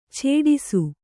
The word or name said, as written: ಚಟ್ಟಿಸು